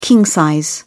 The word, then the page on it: king-size: British English